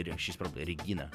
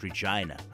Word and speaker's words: Regina: She's probably Regina. That's your Russian. Yeah, Regina Spektor. But in English, you need to pronounce it Regina.